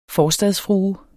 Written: forstadsfrue

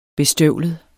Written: bestøvlet

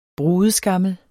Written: brudeskammel

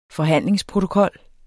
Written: forhandlingsprotokol

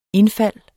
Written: indfald

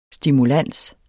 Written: stimulans

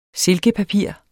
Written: silkepapir

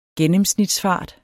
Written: gennemsnitsfart

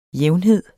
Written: jævnhed

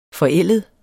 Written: forældet

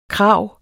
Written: krav

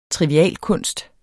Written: trivialkunst

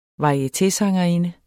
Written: varietésangerinde

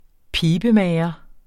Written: pibemager

